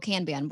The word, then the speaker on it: Kanban: Is it how do you pronounce that? Con Kanban Kanban. What do you know? What I'm talking about Kanban nope. board. Kanban, Kanban board. Yes. – Kanban. – Kanban. That's it.